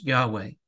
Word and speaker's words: Yahweh: YHWH, which we pronounce Yahweh, means that he depends on no other spirit for his existence.